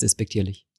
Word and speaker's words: despektierlich: Das heißt so viel, wie du meinst, ist despektierlich. – Ja, das also nein. Bei despektierlich liegt die Betonung auf Dis mit Doppel-S.